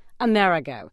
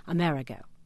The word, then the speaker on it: Amerigo: Well, Amerigo is a good pronunciation because it sounds like America, but Amerigo is the correct pronunciation in the sense that that's the way Florentines pronounce it, and Amerigo Vespucci was a Florentine.